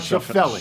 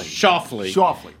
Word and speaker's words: Schauffele: Not Schauffele. – Schauffele.